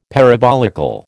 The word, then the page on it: parabolical: American English